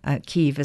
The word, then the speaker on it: Kyiv: Kyiv is how they pronounce it.